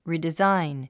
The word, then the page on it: redesign: rìdizáin; リデザァイン